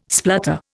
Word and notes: splutter: Indian

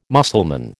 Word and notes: Mussulman: American English

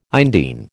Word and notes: indene: American English

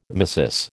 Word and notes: tmesis: American English

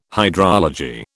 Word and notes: hydrology: British English

